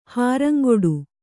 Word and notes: ಹಾರಂಗೊಡು: Kannada

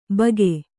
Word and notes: ಭಾಗೆ: Kannada

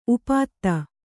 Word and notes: ಉಪ್ಪಾತಿ: uppāti

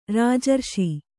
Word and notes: ರಾಜಾರೋಷ: rājārōṣa

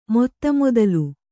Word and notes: ಮೊತ್ತಮೊದಲು: motta modalu

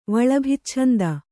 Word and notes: ವಲಭಿಚ್ಛಂದ: valabhicchanda

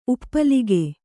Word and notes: ಉಪ್ಪಲಿಕ: uppalika

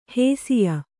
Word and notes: ಹೇಸಿಯ: hēsiya

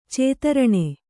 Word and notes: ಚತುರಣ: caturaṇa

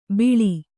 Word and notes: ಬಿಳಿ: Kannada; biḷi

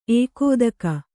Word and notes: ಎಕ್ಕತಕ್ಕು: ekkatakku

